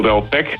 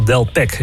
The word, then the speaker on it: Delpech: Ja, dat is net zoiets als Michel Delpech. Dan spreek je de H weer niet uit.